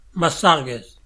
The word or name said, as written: Mastargues